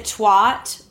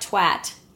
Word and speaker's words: twat: I feel like we pronounce it twat, but I like twat.